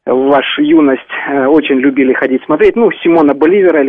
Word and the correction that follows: Боливар: или Боливара, как вы правильно говорите, убили золотой пулей.